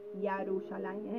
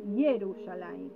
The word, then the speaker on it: Yerushalayim: no es Yarushalayim, es Yerushalayim. Yehoshua.